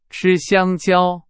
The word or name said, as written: 香蕉